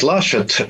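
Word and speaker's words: Laschet: Но я буду говорить Лашет, Армин да, Лашет, да, по-немецки, хотя Лашет. вы правильно говорите, его семья происходит из Бельгии, с французской части, на самом деле по-французски будет Лашет.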